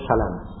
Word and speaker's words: Salam: Qala haddatsana Abu Abdurrahman al-Atati, qala haddatsana Muhammad bin qala haddatsana Muhammad menyelbihkan dia, berarti bacanya Salam, Muhammad bin Salam, fathanya diringankan, tidak ditasydid, tidak dibaca Salam. Kaidah dalam ilmu periwayatan, seluruh kata yang ada Salam begitu, semuanya dibaca Salam, kecuali lima nama.